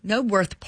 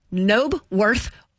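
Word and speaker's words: Knebworth: It was at the Knobworth Park Festival. I said that wrong. I might have to learn how to say that word. Knobworth Park Festival. There you go.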